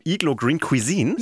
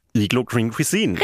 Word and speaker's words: Iglo Green Cuisine: Du meinst die Produkte von Iglo Green Cuisine? – Ja, genau. Die Produkte von Iglo Green Cuisine.